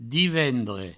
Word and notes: divendres: divendre; Provençal

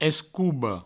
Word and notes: escoba: provençal; escoubo

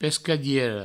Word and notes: pescadiera: Provençal; pescadiero

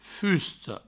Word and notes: fusta: fusto

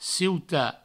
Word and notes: ciutat: cieuta

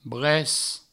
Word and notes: brec: bres

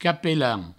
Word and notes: capelan: provençal

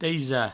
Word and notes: taisar: provençal; teisa

